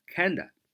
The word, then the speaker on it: kinda: 书面都会这么写，但是口语的时候读作。 Kinda, kinda, kinda 就是有点的意思。